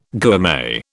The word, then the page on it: gourmet: British English